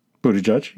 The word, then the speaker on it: Buttigieg: Number one, how to pronounce Buttigieg. – Buttigieg, yeah.